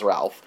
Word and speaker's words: Rolf: Other people who work and live in this house include Bela Lugosi, who plays Rolf, or Ralph. It's spelt Rolf in the credits, but definitely everyone says Ralph.